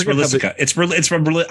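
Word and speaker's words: Rolisica: It's R-O-L-I-S-I-C-A. Rolissicans, right? – I think it's Rolissica. I'm pretty sure it's Rolissica.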